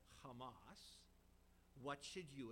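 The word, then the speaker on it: Hamas (חמס): Now, you might be surprised that the English word in our text, violence, is a Semitic word that's pronounced Hamas.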